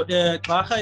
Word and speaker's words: Ugxarha: All right, so Ugxarha is spelled Gxarha.